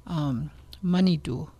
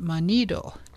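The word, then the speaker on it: manidoo: And when I first started working there, I didn't even know how to pronounce the word Manidu. I thought you pronounced it Manido.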